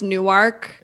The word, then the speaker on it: Newark: It's actually pronounced Newark. – Newark. – Apologies. The Delaware town.